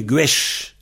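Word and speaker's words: gwezh: Hein, donc, gwes est devenu gwesh, c'est-à-dire le Z, on le prononce CHE.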